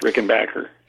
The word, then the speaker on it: Rickenbacker: And then, you know, that's the way John Hall likes it pronounced. Because of the change in the spelling, that's the way it should be pronounced, Rickenbacker.